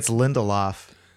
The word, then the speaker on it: Lindelof: You pronounce his name like it rhymes with Lindy Hop, which I appreciate. Lindy Hop. But I think it's Lindelof.